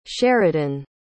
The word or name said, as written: sheridan